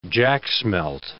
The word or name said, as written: jacksmelt